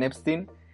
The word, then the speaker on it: Epstein: Y pues viendo la situación, eh, Brian Epstein, que así se pronuncia por cierto, el manager de la banda invirtió pues 40 mil dólares en una campaña de marketing.